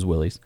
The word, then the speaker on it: Willys: It's not a Willys or a Willis, depending on how you, the proper pronunciation, but everybody says Willys.